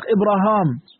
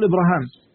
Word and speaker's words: إبراهيم: طيب، قال الله سبحانه وتعالى، طبعا فايدة جماعة ثانية، إن سيدنا إبراهيم كلمة إبراهيم كلمة أعجمية وليست عربية، تمام؟ يعني كل كلمة غير عربية في لسان العرب بيتصرفوا فيها، يعني يلفظونها بطرائق مختلفة. يعني سيدنا إبراهيم ينطق إبراهام، تمام، وبعضهم يقول إبراهام.